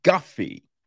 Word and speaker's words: Guffey: So, this is written and directed by Josh Guffrey. Um, uh, oh, no, there's no R. I'm an idiot. It's Guffey right? I believe so. I feel that's how you pronounce it. Josh Guffey, right?